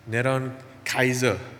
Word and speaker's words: Neron Kaiser: Neron Kaiser is how you pronounce it in Hebrew.